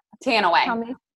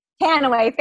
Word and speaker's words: Tanouye: I think Sam and I had expectations for what we wanted to talk about with Dr. Stacy T, who, how do you say her last name again without Tanaway? – Tanaway, thank you.